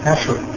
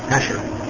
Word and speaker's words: Asher: Asher. – Asher.